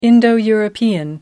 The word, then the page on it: Indo-European: American English